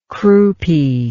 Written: croupy

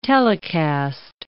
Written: telecast

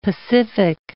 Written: pacific

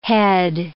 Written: head

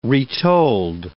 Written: retold

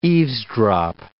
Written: eavesdrop

